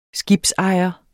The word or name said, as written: skibsejer